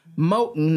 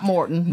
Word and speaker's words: Moton: Moton, not Morton. – How do you spell that? – It's M-O-T-O-N, and the name changed over the years.